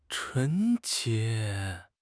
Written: 纯洁

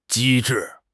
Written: 机智

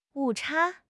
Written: 误差

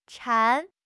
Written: 馋

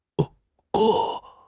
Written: 可行

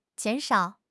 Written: jian3 shao3

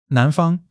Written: nan2 fang1

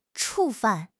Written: 触犯

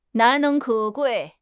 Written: 难能可贵

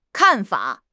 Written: kan4 fa3

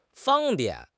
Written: fang1 bian4

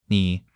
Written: ni3

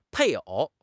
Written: 配偶

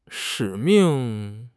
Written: 使命

